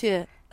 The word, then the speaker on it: tour: Tour. That's how actors used to say it, isn't it? Tour. – Tour. – I remember at drama school we were told that was the correct RP pronunciation of tour.